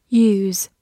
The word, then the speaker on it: use: Use, U S E, use，字母 U 发它本身的音 U，S 发 z，末的 e 不发音。